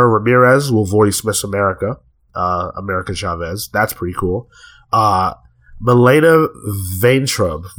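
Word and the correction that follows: Milana Vayntrub: Vaintrub. How do we say that, Kale? – What's her Milana, name? Milana Vaintrub, yeah. – Okay, Milana Vaintrub.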